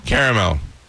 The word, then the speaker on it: caramel: It's three syllables. It's caramel.